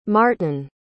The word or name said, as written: maarton